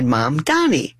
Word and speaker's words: Mamdani: his name, mom and dad, mom, Donnie. My mom, Donnie, you know, my mom's name is Donnie. It's so simple.